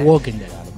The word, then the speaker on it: Walking Dead: Walking Dead.